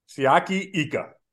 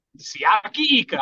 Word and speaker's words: Siaki Ika: Siaki Ika. – Siaki Ika.